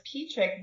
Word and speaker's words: Pietrzyk: My name is Polish, so even though it's pronounced Pietrzyk, there's a Z in it.